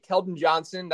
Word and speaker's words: Keldon Johnson: And I got a Keldon Johnson.